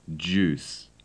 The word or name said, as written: juice